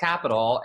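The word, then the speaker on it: Qapital: So she always teases me because I was calling it Quapital for the longest time. She's like, no, it's pronounced Capital.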